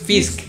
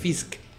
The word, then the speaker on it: Fisk: Este, Wilson Fisk. – Ajá, es que es Fisk. – Fisk. Ah, Con K.